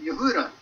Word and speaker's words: Yehuda: "in Yehuda," which is how you say Judah.